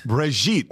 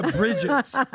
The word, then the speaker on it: Brigitte: By the way, let's pronounce. It's Brigitte. – Brigitte. – It's Brigitte. It's Lebanese. It's got attitude. – Yeah. It has a little bit. I know, apparently a Brigitte.